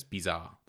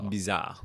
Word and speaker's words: Bizard: So Jacques, his surname is spelled B-I-Z-A-R-D. But I think in French, you drop the D. So it's Bizarre, like Bizarre. – Bizarre.